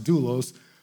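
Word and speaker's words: doulos (δοῦλος): Uh, it's pronounced doulos, but it means to be in permanent servitude to another by being completely surrendered to his or her master.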